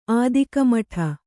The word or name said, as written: ಆದಿಕಮಠ